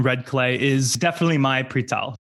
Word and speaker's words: přítel: Is it přítel? – Přítel, kamarád.